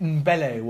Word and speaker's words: Ndombele: And he's had to go for all these new players like Rodri, Ndombele, Hella. You know, they're all gambles. They could be amazing. – But I think they could be shrewd signings. I don't even know how to say it. Ndombele, whatever his name is.